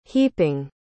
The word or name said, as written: heaping